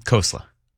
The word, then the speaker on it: Khosla: Khosla. – Khosla? – Yeah.